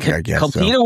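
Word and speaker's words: Kultida: Cultita? Is that how you say it? – Cultita Woods? – Yeah.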